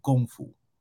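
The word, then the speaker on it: gong fu (功夫): It's not something that it's not a style because we talk about, and also they pronounce it differently in China. They say gong fu.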